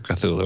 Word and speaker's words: Cthulhu: Am I saying it right, Robbie? Cthulhu? – Yeah. – Yeah. Squid-like looking like monster. – His is Hydra Cthulhu, right? – Well, that's a different story.